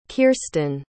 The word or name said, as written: kirsten